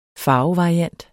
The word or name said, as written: farvevariant